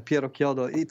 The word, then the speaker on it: Piero Chiodo: I'm doing great, and thank you for saying properly my name, Piero Chiodo. It's an Italian name, of course, and so several people find it a little bit difficult to pronounce it properly.